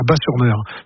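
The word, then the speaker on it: Batz-sur-Mer: La carte postale pour moi, c'est le bidule, et surtout la crêperie s'appelle le Dervin à Bas-sur-Mer. Qui font les meilleures crêpes de la Batte. – Oui, Batte, bon, on prononce Bas, le T ne se prononce pas, Bas-sur-Mer.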